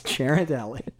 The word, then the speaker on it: Ghirardelli: Well, in San Francisco, Sarah, I can tell you how they say it. It's Ghirardelli.